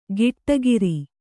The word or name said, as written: ಗಿಟ್ಟಗಿರಿ